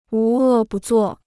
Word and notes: 无恶不作: wú è bù zuò

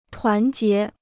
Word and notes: 团结: tuán jié

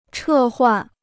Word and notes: 撤换: chè huàn

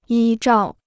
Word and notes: 依照: yī zhào

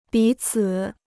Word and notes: 彼此: bǐ cǐ

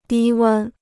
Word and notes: 低温: dī wēn

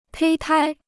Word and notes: 胚胎: pēi tāi

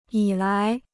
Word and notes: 以来: yǐ lái

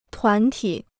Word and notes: 团体: tuán tǐ

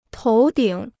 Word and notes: 头顶: tóu dǐng

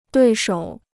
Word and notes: 对手: duì shǒu

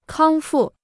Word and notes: 康复: kāng fù

